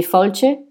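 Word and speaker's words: Fáilte: Et par-dessus, on a mis une grille et on a écrit Bienvenue en gaélique donc ça se dit falche », ça s'écrit Falte, ça ne se prononce absolument pas comme ça s'écrit.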